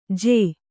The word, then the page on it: ಜೂ: jū